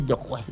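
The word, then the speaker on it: Doué: ¿Y cómo se dice el apellido? – Así se dice Dojue.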